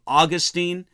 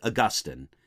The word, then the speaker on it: Augustine: Sometimes people pronounce his name Augustine. If I pronounce his name during this lecture that way, I'll try to use the more commonly accepted Augustine.